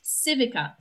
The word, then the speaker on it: Civica: It's Civica, Civica. Looks like Civica. – But it's pronounced Civica. – Civica. It's Italiano. See, I just gave an Italian lesson.